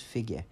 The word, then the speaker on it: Figguh: Figge, alltså det uttalas Figge, men det stavas F-I-G-G-U-H, Figge.